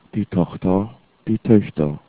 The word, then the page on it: tochter: german